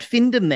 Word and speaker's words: findom: Now I, I said Findom there. Is that how you'd pronounce that? – Uh, Findom is yeah is how I would yeah. say it.